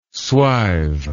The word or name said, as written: swive